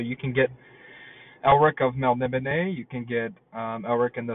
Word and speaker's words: Melniboné: I always say Melniboné, because it's, well, one, it's fun to say, like, it's an interesting way to say it, and also it sounds kind of more fancy. Plus, Melniboné, they have an accent on the E there, so I think you gotta say it like that, man. I think it's Melniboné. Melniboné.